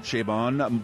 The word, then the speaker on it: Chabon: Chabon.